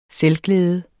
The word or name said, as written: selvglæde